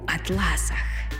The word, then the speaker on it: атлас: «Обідньої вже доби сама пані вкотила, як на колесах, пухла така у перснях, у атласах». Омографічна пара до цього слова – грецизм, тобто грецька за походженням лексема «атлас» з наголосом на перший склад,